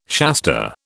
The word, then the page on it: Shasta: British English